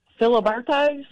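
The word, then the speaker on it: Filiberto's: Say it again. – Filibertos? – There you go. That's the right way to say it.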